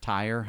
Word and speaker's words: Tyre: But like in Isaiah, there's a talking about the city of Tyre, how you pronounce it, T-Y-R-E.